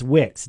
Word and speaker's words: Wicks: And I think you may have said that name wrong last week. In fact, it is Wicks, W-I-C-K-S, a graduate of Natomas High School.